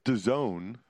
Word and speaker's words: DAZN: It's pronounced DAZN. A lot of people don't know that, actually, Jack.